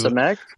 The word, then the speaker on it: Samek: Samek, Okay.